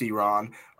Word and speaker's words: Iran: It's pronounced Iran.